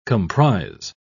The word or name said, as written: comprise